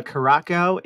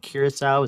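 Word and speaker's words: Curaçao: And did you know that Caraco is how we say it on the East Coast and Curacao is how they say it on the West Coast? – Oh, wow.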